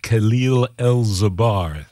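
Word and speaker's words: Kahil El'Zabar: Well, I want to go over to a track from Chicago percussionist Khalil El-Zabar. That's spelled K-A-H-I-L, Khalil, and then E-L apostrophe Z-A-B-A-R.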